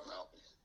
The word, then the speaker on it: Bill Melton: Belton, Bill Melton. And then Willie Stark. – Bill Melton. – Did I say it wrong? – No, you're right.